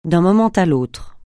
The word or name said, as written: d'un moment à l'autre